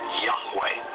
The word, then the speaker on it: Yahweh: Today, Yud-Heh-Wah-Heh is most often rendered as Yahweh when written or spoken by Christians and others. It is still never pronounced aloud by Orthodox Jews. The pronunciation Jehovah is an older, less accurate rendition of these letters.